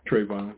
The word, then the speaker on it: Trayvon: Did I say the name right? – Trayvon. – You know, Trayvon, there you go.